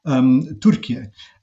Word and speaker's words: Türkiye: It's a separate letter in Turkish, pronounced U, Türkiye, I-Y-E.